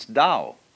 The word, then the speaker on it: Tao: Do is the way the Japanese pronounce Tao.